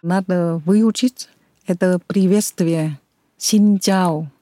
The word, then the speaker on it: Xin chào: Так, тогда, подождите, давайте научимся правильно говорить приветствие. – Xin chào. – Xin chào. – Xin chào. – Xin chào. – Xin chào. – Правильно.